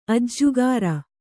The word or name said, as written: ಅಜ್ಜುಗಾರ